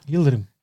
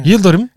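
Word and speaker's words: Yıldırım: How do you say his name like that? – Yildirim. – Sorry, I said Yildirim. – Yeah. – Yildirim.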